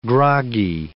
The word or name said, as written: groggy